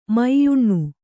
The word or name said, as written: ಮ್ಯಾನಾ